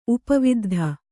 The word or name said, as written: ಉಪವಿದ್ಧ